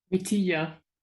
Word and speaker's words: Vitija: Vitija.